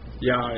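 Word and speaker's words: IAH: For example, if you went to Psalm 68:4, you would see in the original King James that it is a capital I, capital A, capital H, pronounced Yah.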